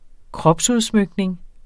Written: kropsudsmykning

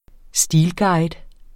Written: stilguide

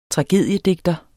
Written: tragediedigter

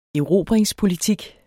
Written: erobringspolitik

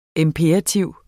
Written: IMPERATIVER

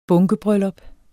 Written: bunkebryllup

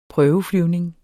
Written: prøveflyvning

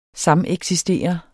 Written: sameksistere

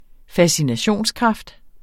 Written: fascinationskraft

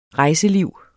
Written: rejseliv

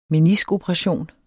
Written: meniskoperation

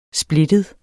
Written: splittet

